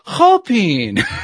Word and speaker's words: Chopin: And so I spelled it out for her, and she says, "Oh, Chopin." – Wow. – What do you mean, Chopin? So apparently that's the correct pronunciation of his name, Chopin. But I never knew that.